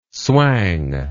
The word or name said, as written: swang